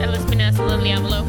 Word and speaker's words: El Espinazo del Diablo: El Espinazo del Diablo.